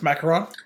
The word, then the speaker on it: macaron: It's macaron.